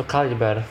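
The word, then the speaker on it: Calibre: Jeżeli ktoś zna angielski, nawet jeżeli wam się wydaje, że macie dobre źródła, to ja naprawdę polecam się do Kalibri i tak swoją drogą zapisać, to Kaliber właściwie, bo to tak się powinno wymawiać, bo to brytyjska pisownia.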